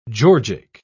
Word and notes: georgic: British English

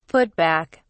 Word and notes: put back: British English

